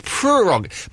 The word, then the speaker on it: prerogative: Well, I've been saying prerogative, but it's not, it's prerogative.